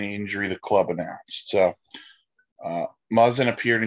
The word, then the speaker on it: Muzzin: As he's going to take us into the first bit of news, and that's, I'm going to butcher the guy's name, and even though it's a simple one, Jake Muzzin. – Muzzin. – I told you I was going to butcher it.